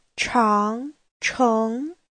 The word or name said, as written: chang2cheng2